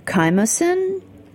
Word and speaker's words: Chymosin: I do like your, it's a picture of rennet, the chymosin? – Oh, yeah. – Is that how you say it, chymosin? – Chymosin, that's right.